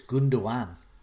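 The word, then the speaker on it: Gündoğan: I've been told not to pronounce the last G in the live chat, so it's Gundogan.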